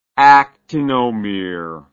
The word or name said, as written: actinomere